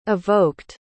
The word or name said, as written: evoked